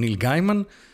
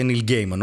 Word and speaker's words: Neil Gaiman: Δεν ξέρεις τον Neil Gaiman? Το οποίο είναι λάθος προφορά, λέγεται Neil Gaiman ο άνθρωπος, αλλά δεν έχει σημασία.